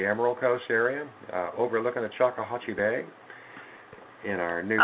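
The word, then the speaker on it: Choctawhatchee: Oh, you finally said it right. – Well, I always say it right. Haven't heard you say it yet. – Well, I don't. I said Chocolate Bay. – Chocolate Bay. Well, it's not chocolate, though.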